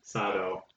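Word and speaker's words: Sadao: It's S-A-D-A-O. Sado? I don't know. How do you pronounce that? – Sado.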